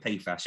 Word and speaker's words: PFAS: PFAS? – That's how we pronounce it. There are, I think, various other pronunciations, but we start with PFAS.